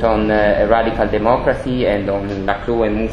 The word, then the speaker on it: Laclau: how do you pronounce it? French? – No. – Laclau, okay.